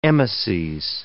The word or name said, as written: emeses